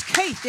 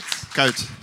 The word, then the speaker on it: Kuyt: You taught me how to say it today. It's Kuyt, isn't it? – Kuyt.